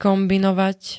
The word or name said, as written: kombinovať